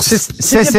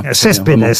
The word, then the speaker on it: Cespedes: Comment j'ai dit d'ailleurs ? – Cespedes. – À l'espagnol. – Est-ce que je prononce bien d'ailleurs ? – Très bien. – Cespedes.